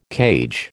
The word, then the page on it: cage: American English